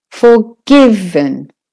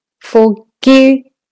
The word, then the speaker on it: forgiven: Forgiven. Forgiven. Forgiven. The G is the stressed part in there.